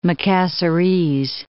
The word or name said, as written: Macassarese